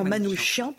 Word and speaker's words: Manouchian: Euh, j'aimerais qu'on écoute André Manoukian cette fois-ci, parce qu'il y a tout un débat sur euh, on prend, comment prononce-t-on Manouchian Manoukov Manouchian. Alors c'est vraiment Manouchian,